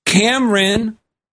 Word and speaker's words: Kamran: And his name, I'm going to mention it on the air, is Kamran Karimi. And it's spelled Kamran, not like Cameron Crowe, but K A M R A N.